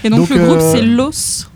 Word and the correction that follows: Loathe: Los, je ne sais pas comment on le prononce. L-O-A-T-H-E. Los, okay. Je pense. Le titre c'est Gord. – Ok. – Ce qui veut dire breakdown. – Ah, c'est Loathe. L-O-A-T-H-E, Loathe.